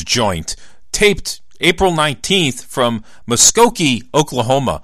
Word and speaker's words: Muskogee: I really had to think hard how to pronounce that, but the easiest way to remember how to pronounce that is the old Merle Haggard song, Okie from Muskogee.